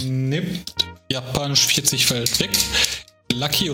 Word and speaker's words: Lucky: Äh, es wird Raki ausgesprochen, sehr wahrscheinlich, aber äh, ausgesprochen, wie wir es lesen würden, Lucky, wie glücklich, er hat Glück man hat Glück. L-U-C-K-Y.